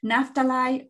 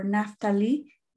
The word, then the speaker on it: Naphtali: Naphtali or Naphtali, you can pronounce it either way.